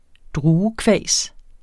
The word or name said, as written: druekvas